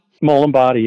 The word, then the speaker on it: Molin Body: Yeah, I want to talk a little bit about that because you worked at this, am I saying the name right, Molin Body? – Molin Body, yeah.